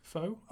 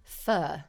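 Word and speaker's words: pho: A steamy foe. Oh, a foe. – Wrong. – What is it? – It's actually pronounced, apparently, me and Harry found this out the other day, fur.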